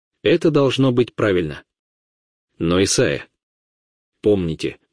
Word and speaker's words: Исаия: Пророк Исаия, или вы говорите Исаия? Мне всегда приходится объясняться. Я ходил в школу и работал в аспирантуре в Шотландии, и именно так произносили Исаию.